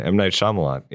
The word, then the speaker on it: Shyamalan: Wait, how do you say it? I've forgotten. M. Night Shyamalan. Yeah. Okay. Yeah. There we go.